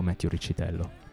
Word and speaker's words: Riccitello: We say Riccitello in the U.S., but I think the true Italian pronunciation is Riccitello, but it's kind of been Americanized, I think, in the last couple years.